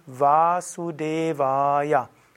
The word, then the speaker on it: Vasudevaya: Also nicht wie beim Deutschen, vielleicht machen wir Suse, sondern Vasudevaya. Und auch das E ist lang. Vasudevaya.